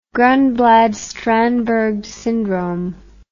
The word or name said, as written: Gronblad Strandberg syndrome